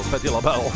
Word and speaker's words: Patti LaBelle: Patti LaBelle, precies goed uitspreken.